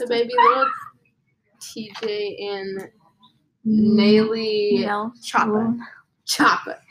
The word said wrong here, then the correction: NLE Choppa: It's NLE Choppa. – NLE Choppa. Choppa. – You said Nelly.